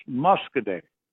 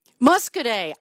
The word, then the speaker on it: Muscoda: That's pronounced Muscoday. – Muscoday. I get this wrong every time. You keep correcting me, and one of these days I'll get Muscoday right. Thank you for your correction, friend. Go ahead. – Well, I think that might be our way of pronouncing it, but maybe the Indians, the Native Indians, pronounced it Muscoda.